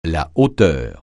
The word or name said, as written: hauteur